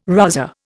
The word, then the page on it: rozzer: Indian